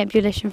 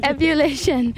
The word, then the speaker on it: ebullition: I was saying it wrong, actually. It's ebullition, not ebullition. But anyway, bubbles.